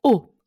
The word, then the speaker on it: Haux: Also Haux, falls ihr es noch nicht wusstet, wird O ausgesprochen.